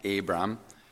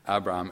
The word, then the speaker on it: Abram: When the Lord calls him, and said, the Lord says to Abraham, or at the time, Abram. I'm not sure if you can hear the difference in the pronunciation there. Maybe I'll just pronounce it Abram, okay? Abram and Abraham.